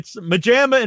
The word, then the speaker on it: MaJaMa: How do you say it? – MaJaMa. – Oh, it's MaJaMa and pajamas. – It's literally just like pajama. – But just think of Matthew, Jason, Mateo, MaJaMa.